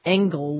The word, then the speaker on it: engel: The word angel in English is just pretty much a fusion of the old English word engel with a hard G and the old French word angel.